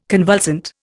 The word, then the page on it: convulsant: Indian